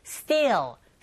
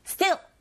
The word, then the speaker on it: steel: copper, steel, steel 짧은 게 아니라 길게 발음하셔서 steel 맨 끝에 L 사운드, 네, 다크 L 사운드인지라 혀끝이 윗니디로 붙어주셔야 되고 and sphericoppers 이렇게 발음하시면 됩니다.